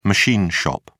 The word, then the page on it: machine shop: British English